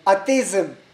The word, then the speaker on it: atheism: Atheism.